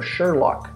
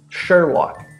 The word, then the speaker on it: Sherlock: That's the sound, so Sherlock, okay? Sherlock. This is American pronunciation. British, Sherlock. Right?